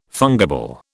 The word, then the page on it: fungible: British English